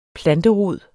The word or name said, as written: planterod